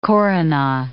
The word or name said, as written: koruna